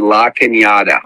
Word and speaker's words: La Cañada: Steve, can you pronounce that correctly? Follow me. La Cañada. – I've always pronounced it correctly, but okay.